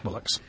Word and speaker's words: Bolix: B-O-L-I-X, and pronounced Bollocks.